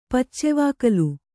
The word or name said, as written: ಪಚ್ಚೆವಾಕಲು